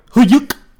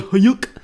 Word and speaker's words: Huyck: His name is literally spelled like Huyuk. – Yeah, his name is what is it? H-Y-U-C-K? – H-U-Y-C-K. – H-U-Y-C-K, sorry. – Yeah, written by Willard Huyuk.